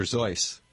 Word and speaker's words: Seuss: Oh, that's a Dr. Seuss. – Right, or pronounced correctly, Dr. Zeuss.